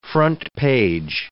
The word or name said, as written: front-page